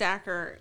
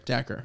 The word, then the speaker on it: Daiquiri: Yeah, it's pronounced Daiquiri. – Daiquiri, yep. – It says... Acre, like acre. – Gotta lean in a little bit more. – It says like acre with a D.